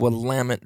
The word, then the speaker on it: Willamette: I think it's actually said Willamette Valley Waterfowler. Uh, this was on Tuesday. Solid, non-biased information, five stars. And the only reason I think I know how to pronounce it, I'm pretty sure it's Willamette correct me if I'm wrong.